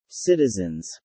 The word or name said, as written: citizens